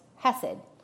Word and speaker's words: chesed: So the Hebrew word that linguists have translated as kindness is spelled C-H-E-S-E-D, and it's pronounced hesed. So if you're familiar with the spelling of Hallebred or Hanukkah, that silent C at the beginning will make a little more sense to you.